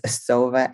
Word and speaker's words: Sova: And I did think I said it wrong, but it's Sova, S-O-V-A _ nutrition RDN.